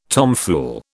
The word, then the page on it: tomfool: British English